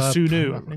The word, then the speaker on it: Kossounou: Um, another Bayer Leverkusen player that Spurs have been linked with is Odilon Kusudu. Uh, I'm not sure if I've uh, Kossounou. Kossounou.